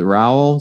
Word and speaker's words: Rowell: But in the assuming this shows up the same way once it hits YouTube, you have Michelle, is it Rowell? Is that how you pronounce it? – Yep, right? Like Powell with an R.